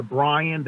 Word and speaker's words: Brian: And it's called Missing You, and you can go on Spotify, Apple Music, and you can find the song under Brian, that's B-R-I-A-N, and Catania.